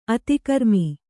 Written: ಅತಿಕರ್ಮ

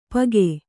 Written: ಪಗೆ

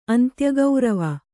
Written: ಅಂತ್ಯಗೌರವ

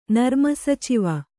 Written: ನರ್ಮಸಚಿವ

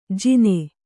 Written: ಜಿನೆ

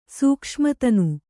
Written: ಸೂಕ್ಷ್ಮತನು

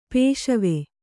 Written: ಪಸಿವು

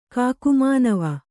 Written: ಕಾಕುಮಾನವ

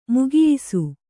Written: ಮುಗಿಯಿಸು

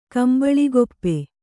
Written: ಕಂಬಳಿಗೊಪ್ಪೆ

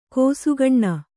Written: ಕಿಸುಗಣ್ಣು

